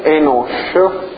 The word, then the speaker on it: Enosh: Allora, c'è il termine Enosh, che significa questo archetto sopra, no? Indica che si legge Sh, l'Africa sì.